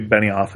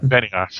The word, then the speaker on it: Benioff: I believe Benioff is how you pronounce that. – Benioff. Ah, good.